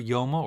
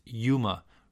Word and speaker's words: Yoma: The word Yoma, or probably more correctly pronounced Yuma,